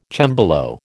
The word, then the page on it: cembalo: American English